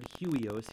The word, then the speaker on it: huios: Well, if you go back to the Greek word used here, the Greek word, and I might say it wrong, but it's huios, huios, or H-U-I-O-S, huios.